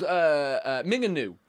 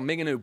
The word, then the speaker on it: Mingenew: It was called Minganu. All right, it's called Minganu, but it's spelled Mingenew.